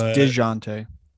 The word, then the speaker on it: Dejounte: It's Dejounte.